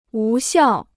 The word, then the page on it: 无效: wú xiào